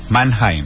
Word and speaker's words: Mannheim: Ojo que te corrigieron ahí, ¿cómo se dice? – Mannheim. – Uy, y esto se debe a... – Todo alemán, ¿no? – Sí, todo alemán.